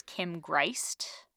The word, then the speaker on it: Greist: Kim Greist, I believe is how you say her name.